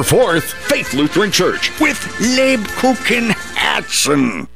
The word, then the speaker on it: Lebkuchenherzen: Lebkuchen Herzen. – The Kupenhergens Herzen.